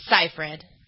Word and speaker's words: Seyfried: Is it Seyfried? Is that how you pronounce it? – Seyfried.